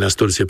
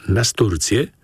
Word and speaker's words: nasturcję: Mianowicie w tym pierwszym utworze Janusz Kruk trochę dziwnie nasturcję potraktował. Mówimy przecież nasturcję, a nie nasturcję.